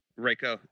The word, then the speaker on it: Reiko: All right, another good for me was the Jax Ryoko fight. – Reiko, yeah.